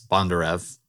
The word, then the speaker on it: Bondarev: And you basically have an interview with this guy, Boris Bondarev. Correct me if my pronunciation is wrong. – Yep, that was it. That was perfect.